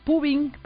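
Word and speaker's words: phubbing: Una palabra se llama cibercrastinación y la otra, fíjense qué curioso, se llama pubing, pero se escribe P-H-U-W-I-N-G. Vamos a ver de qué se trata.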